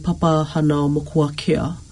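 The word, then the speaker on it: Papahānaumokuākea: And so this marine area in Hawaii called Papahanao Mokuakea. Doesn't it sound so cool? – I know. Just as you said it, your pronunciation, bang on.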